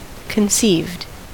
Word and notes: conceived: American English

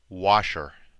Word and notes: washer: American English

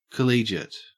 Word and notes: collegiate: Australian English

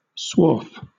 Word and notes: swarf: British English

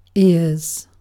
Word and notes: ears: British English

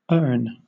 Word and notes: urn: British English

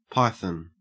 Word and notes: python: Australian English

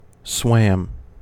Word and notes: swam: American English

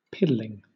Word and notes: piddling: British English

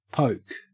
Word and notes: poke: Australian English

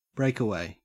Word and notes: breakaway: Australian English

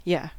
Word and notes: you: Australian English; American English; unstressed

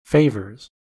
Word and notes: favours: American English

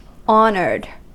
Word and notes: honoured: American English

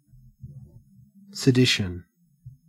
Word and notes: sedition: Australian English

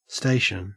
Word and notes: station: Australian English

